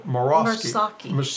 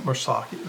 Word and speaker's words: Murasaki: I'm not saying this right. I just pronounced it right this morning. Murasaki. Murasaki. That is right.